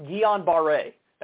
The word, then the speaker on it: Guillain-Barré: It's pronounced Guillain-Barré. That's how it's pronounced.